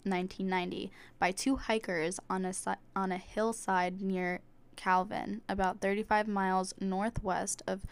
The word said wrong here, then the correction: Calvine: I think it's pronounced Calvine with the E at the end, but... – Calvine. – Calvine. Sorry. Calvine in Scotland, I guess.